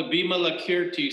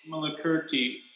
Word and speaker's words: Vimalakirti: It's called the Vimalakirti Sutra. – Okay. – It's spelled V-I-M. – V-I-M. – Vimala. V-I-M-A-L-A. – A-L-A. – Vimalakirti. K-I-R-T-I or something like that.